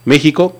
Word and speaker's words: México: O puedes decir México, que ahí sería como J.